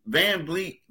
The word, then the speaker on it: VanVleet: I mean, because the thing is, VanVleet, I always mispronounce the guy. – I want to say Fleet every time.